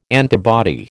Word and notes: antibody: American English

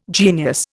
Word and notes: genius: Indian

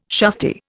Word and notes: shufti: Indian

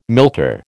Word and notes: milter: American English

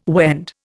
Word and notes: Wend: Indian

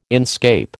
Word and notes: inscape: American English

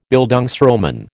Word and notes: Bildungsroman: American English

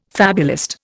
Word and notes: fabulist: Indian

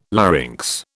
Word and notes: larynx: British English

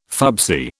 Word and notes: fubsy: British English

